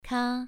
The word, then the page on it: ka: 2